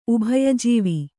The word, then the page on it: ಉಭಯಜೀವಿ: ubhaya jīvi; kannada